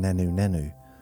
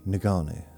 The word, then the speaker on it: Ngannou: Now, for Ngannou, I could say his name a minute ago, I want to keep saying Nanu Nanu from Mork and Mindy. When Nanu Nanu, Ngannou. Got to be really careful how I say that word, that name. Ngannou.